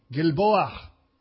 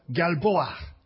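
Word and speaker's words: Gilboa: Try this word with me. Gilboah. Try that. Give it a try. Galboah. Got to give me that H in there. Some of you know how to pronounce that kind of thing at the end.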